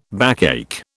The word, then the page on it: backache: British English